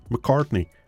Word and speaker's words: McCartney: Aujourd'hui, c'est la légende britannique de la chanson, hein, à savoir Sir Paul McCartney. Euh, lui, je prononce son nom correctement parce que c'est cela,